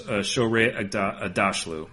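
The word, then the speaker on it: Shohreh Aghdashloo: So I think that's Shoreh Agdashloo, I think is how it's pronounced.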